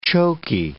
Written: choky

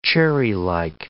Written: cherrylike

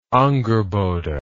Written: Angrboda

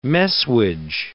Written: messuage

